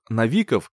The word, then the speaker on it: Навиков: Ударение в фамилии на второй слог «Навиков» от слова «Навик», а не от слова «Новости», не уставал повторять преподаватель.